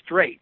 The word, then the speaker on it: Streit: A guy by the name of Clarence Streight, which is spelled Streit, wrote a book called Union Now.